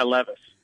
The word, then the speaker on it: Levis: If they wouldn't get, is it Levitt? Is that how you say it, the kid from Connecticut? – Yeah, Levis.